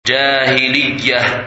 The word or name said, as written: Jahiliyah